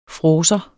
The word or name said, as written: fråser